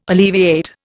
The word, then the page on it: alleviate: Indian